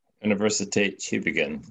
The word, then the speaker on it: Universität Tübingen: Universität Tübingen. Hopefully that's close. – Universität Tübingen.